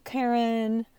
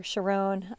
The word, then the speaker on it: Charon: So, you know, they make references to the Bed of Procrustes and the Book of Charon or Charon. I think you can pronounce it either way.